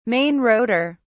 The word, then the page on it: main rotor: British English